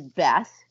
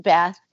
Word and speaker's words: Beth: I lived in Winnipeg at the time, and his relative came from the UK, and my name is Beth, B-E-T-H. It's not short for anything. It's not short for Elizabeth. It's just Beth, and this kid's cousin or whomever could not pronounce my name. He just kept calling me Beth, like with an F sound.